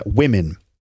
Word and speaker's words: women: The O is pronounced with an I sound, and the E is also pronounced with an I sound. So we have women, okay? One woman, two women, and then one man and two men. But it's the plural women that people often don't pronounce correctly. Uh, women, okay?